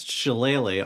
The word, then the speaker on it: shillelagh: He casts Shillelagh on the air. – Oh, what's the spell? How do you say it? – Shillelagh.